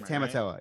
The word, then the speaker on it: Tamatoa: "Look for Tamatoa." Am I saying the name right? – Close enough. Yeah, Tamatoa.